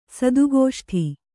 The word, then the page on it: ಸದುಗೋಷ್ಠಿ: sadu gōṣṭi